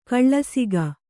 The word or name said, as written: ಕೆಲಸಿಗ